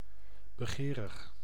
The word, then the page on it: begerig: IPA: /bəˈɣeːrəx/; Dutch